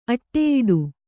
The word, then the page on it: ಅಡಿಯೂಡು: aḍiyūḍu